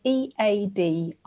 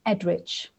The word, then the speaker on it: Eadric: I mean, it's spelled Eadric. I'll get onto his so-called surname in a second. But basically, at the end of Old English names, if there's a C, it tends to be pronounced ch rather than a hard ch. And at the beginning, you might think, okay, so that's Eadric. But actually, the EA is the same way that the names we still use today were spelt.